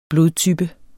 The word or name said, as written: blodtype